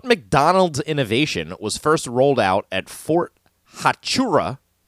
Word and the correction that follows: Huachuca: near Sierra Vista, Arizona, 1975? I know I'm butchering that name. Fort Huachuca near Sierra Vista, Arizona, in 1975.